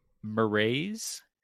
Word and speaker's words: Marais: I'm actually real curious to see if Paul can spell this one, but I'm going to go with Marais. Marais? – Okay. – Is it pretty close? – Yep, that is how they say it in the audiobook.